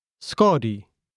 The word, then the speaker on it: skadu: That is S-K-A-D-U, skadi.